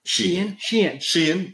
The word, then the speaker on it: Sheehan: Sheehan.